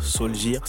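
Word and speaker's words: Solskjær: Seulement voilà, depuis la situation a beaucoup changé, Mourinho a été licencié, il a été remplacé par Ole Gunnar Solgir c'est comme ça que ça se prononce.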